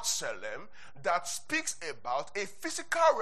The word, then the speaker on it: tselem: The word image is the Hebrew word tselem. I'll spell it for us. T-S-E-L-E-M. I'll spell it for us. The word their image is the word tselem. Tselem. Tselem. So, tselem, yeah, it's spelled T-S-E-L-E-M.